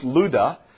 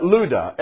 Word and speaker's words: Lydda: It looks like Lydda, and it's pronounced Luda. At Luda, and there he found a certain man named, and we normally would pronounce it Aeneas, and it's Aeneas.